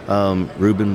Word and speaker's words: Reuben: It's just my name, Reuben Bolieu.